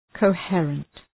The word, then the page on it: coherent: kəʋ’hırənt